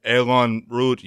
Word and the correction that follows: Eau Rouge: You want to take that? I know you're better at pronouncing stuff than me. – Eau Rouge, yeah.